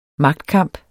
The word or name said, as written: magtkamp